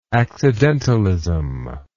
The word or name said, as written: accidentalism